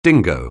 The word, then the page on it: dingo: anglais